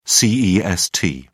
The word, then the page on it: cest: British English